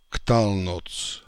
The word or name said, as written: Ktalnotz